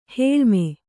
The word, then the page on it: ಹೇಳ್ಮೆ: Kannada